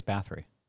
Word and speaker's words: Bathory: She basically plays a version of Elizabeth Bathory. I'm going to say that wrong. – I think it's Bathory.